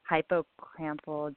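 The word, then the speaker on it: hippocampal: So there's another study, is the real life hypocampal dependent spatial memory impairments in Huntington's disease. I probably said that wrong too. So what is that? – Yeah, you did pretty good.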